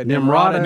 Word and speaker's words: Nimrata: Yeah, Nimrata.